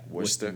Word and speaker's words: Worcester: But are you ready for the actual pronunciation of it? – Let's do it. – Good old Worcester. Yeah, Worcester, Mass. I mean...